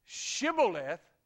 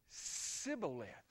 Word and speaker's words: Shibboleth: The Gileadite knew that the Ephraimites, for whatever reason, the Bible doesn't tell us, could not pronounce the SH sound. And so when that Ephraimite said Shibboleth, it came out Sibboleth.